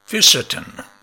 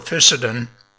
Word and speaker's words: Fisetin: Fisetin, the remedy you never heard of. I was reading an article recently by Dr. Joseph Mercola on the flavonoid fisetin that fascinated me. Fisetin is spelled F-I-S-E-T-I-N.